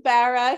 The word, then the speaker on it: Barra: Well, my husband's from the East Coast, so it's Barra, like a Barra. Barra. – I have Millie Barra here with me, and I'm so excited to be talking to her.